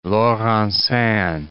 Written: Laurencin

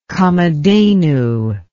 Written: kamadhenu

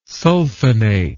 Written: sulfonate

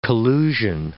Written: collusion

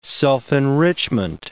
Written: self-enrichment

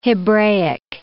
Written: Hebraic

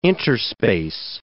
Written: interspace

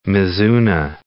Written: mizuna

